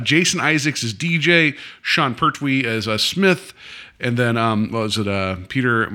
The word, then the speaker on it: Pertwee: Jason Isaacs and Sean Petrie or it's Pertwee. Pertwee? Yeah.